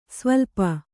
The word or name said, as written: ಸ್ವಲ್ಪು